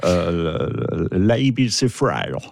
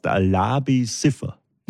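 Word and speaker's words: Labi Siffre: Weiß jeder, wie man ihn ausspricht. Das ist Labi Siffer. – Ich glaube, so heißt er nicht. – Nein, er heißt Labi Siffer. – Na, siehst. – Das ist richtig ausgesprochen.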